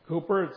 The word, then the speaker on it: Cowper: It's pronounced Cowper, it's spelled C-O-W-P-E-R, when you see it in your hymnal.